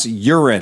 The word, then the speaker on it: Huron: So you went to Bora's Cycle Salvage, which is in Huron, Ohio, which the locals pronounce Urine.